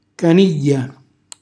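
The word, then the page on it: canigghja: Calabrian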